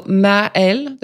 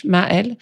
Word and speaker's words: Maëlle: So Maëlle, the first name, Ma like mom, and then Elle like she in French, Maëlle. And then in French, you usually don't pronounce the last letter. So my last name is Gavé. You don't pronounce the T. – This week, Maëlle Gavé, CEO of Techstars, the biggest, most active pre-seed investor in the entire world.